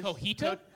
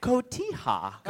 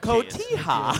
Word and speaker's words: Cotija: It's pronounced Cotija. – Cotija.